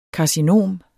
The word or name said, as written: karcinom